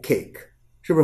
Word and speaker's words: cake: 这时候我们看到的这个字母 E，看好啊，这个字母 E 它不发音，这个 A 它就发了 A 的音，所以说 K A K Cake。是是不是很简单？